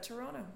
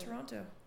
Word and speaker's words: Toronto: That's how you pronounce that. Toronto, not Toronto.